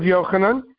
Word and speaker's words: Yochanan: Mattathias, the son of Yochanan, the son of Shimon, a priest of the sons of Yoariv. That's how the Hebrew is pronounced.